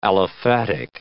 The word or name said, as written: aliphatic